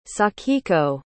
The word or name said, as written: sakiko